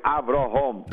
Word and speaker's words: Avrohom: We had to make underlined because when God says to Avrohom, Avrohom, then we want to make sure that everyone pronounces the proper way, Avrohom.